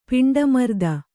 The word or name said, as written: ಪಿಂಡಮರ್ದನ